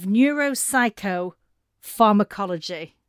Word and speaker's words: neuropsychopharmacology: Before we get into the book and everything else we've got to talk about, would you mind just answering a question, and bear with the pronunciation on this? You're a professor of neuropsychopharmacology. – Correct. Well said.